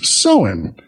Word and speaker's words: Samhain: And she sent this story about Samhain, which is spelled Samhain. Most people incorrectly call it Samhain. It is actually pronounced Samhain.